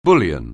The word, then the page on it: bullion: anglais